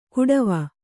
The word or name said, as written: ಕುಡವ